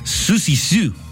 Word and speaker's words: Siouxsie Sioux: Hoy cumple 69 años la artista británica Siouxsie Sioux. Se pronuncia, perdóneme usted, Susie Sue. Ahí está más fácil.